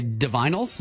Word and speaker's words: Divinyls: I Touch Myself by Divinyls.